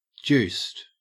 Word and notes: juiced: Australian English

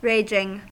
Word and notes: raging: American English

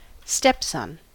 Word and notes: stepson: American English

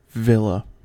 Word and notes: villa: American English

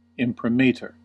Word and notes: imprimatur: American English

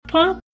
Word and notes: punk: American English

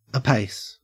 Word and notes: apace: Australian English

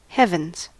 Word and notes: heavens: American English

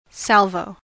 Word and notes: salvo: American English

